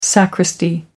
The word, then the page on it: sacristy: American English